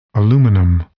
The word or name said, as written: aluminum